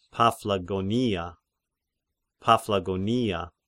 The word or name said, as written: Paphlagonia